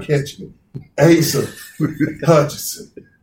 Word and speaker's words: Hutchinson: You got Asa Hutchins. – Yes. – Is it Hutchinson? – Hutchinson. It really doesn't matter.